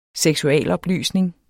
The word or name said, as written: seksualoplysning